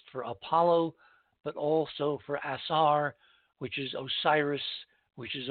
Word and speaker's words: Asar: And Orion, of course, in the Egyptian, if it's anglicized, is pronounced Asar. A-S-A-R.